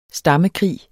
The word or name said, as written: stammekrig